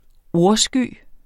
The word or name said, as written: ordsky